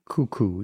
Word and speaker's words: Coucou: And the brand is called Cuckoo Intimates. Is that how you say it? C-O-U-C-O-U? – Cuckoo, yeah.